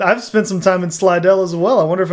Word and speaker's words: Slidell: I'm, am I saying that right, or is yes, Slidell? That is correct. Not Slidell.